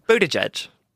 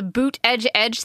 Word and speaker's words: Buttigieg: Buttigieg. – Buttigieg. – Buttigieg. – Yeah. – But I'm not going to lie, the boot edge edge thing is very confusing to me.